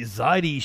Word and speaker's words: Zaidi: Houthis, an Iranian-backed Sadie? I'm totally fucking that one up. Some of the YouTube videos I have to read have names I have to stop and learn how to pronounce. I'm probably saying it right. – Zaidi. – The Zaidi Shiite group.